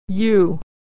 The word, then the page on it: eu: /y/